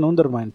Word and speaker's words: Undermine: se llama, sí, se llama, lo pronunciaste bien, Undermine, pero.